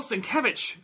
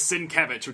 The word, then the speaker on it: Sienkiewicz: Bill Sienkiewicz, which is how you pronounce his name. – Yes. – Is Sienkiewicz, which I learned when I worked at Wizard, because that was like one of the first things, like, how do you pronounce this guy's last name? Because it is Sienkiewicz is, I think, how I pronounced it when I was like a little kid. – Sienkiewicz? – Yeah. – Because sounds like a game. – Yeah. Sienkiewicz.